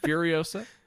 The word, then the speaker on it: Furiosa: She is the better Fury Rosa. You guys know what I'm saying. Furiosa.